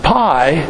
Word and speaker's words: pi: In fact, in Greek, you pronounce it p, not pi, but we'll usually call it pi, because that's what people normally do.